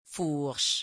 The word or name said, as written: fourche